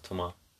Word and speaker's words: Thomas: Vincent Cassell as Thomas Leroy or the Gentleman. – Tomar. It's pronounced Tomar.